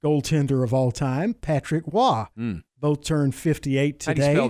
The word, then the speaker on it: Roy: It's tough, but it looks every bit like it would be Roy. – Oh, absolutely. But it's not. Absolutely. That French Canadian pronunciation.